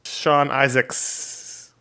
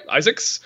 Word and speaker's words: Isaacs: Artist is Sean Isaacase. How would you say that? – According to his Twitter account, it's Sean Isaacs. – Okay, Isaacs.